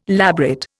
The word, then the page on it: labret: Indian